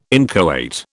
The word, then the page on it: inchoate: British English